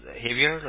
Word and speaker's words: Javier: J-A-V-I-E-R. – Okay. You pronounce it as Javier, like Xavier? – Javier.